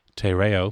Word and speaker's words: Te Reo: she is studying Te Reo. Am I pronouncing that correctly? – Yeah, kind of. – Okay, Tereo, which is the Maori language.